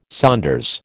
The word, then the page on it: saunders: American English